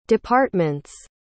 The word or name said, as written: department's